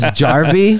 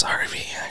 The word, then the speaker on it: Harvey: But it was spelt with a J, and then the bro let me walk around all day and introduce him to everybody as Jarvey. – What's up, Jarvey? It's Harvey, actually.